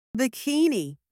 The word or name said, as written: bikini